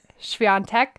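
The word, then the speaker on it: Świątek: Schwinteck.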